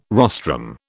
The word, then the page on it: rostrum: British English